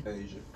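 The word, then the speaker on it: A'ja: But you're not gonna go watch Ajay Wilson, or how you say her name? – Asia.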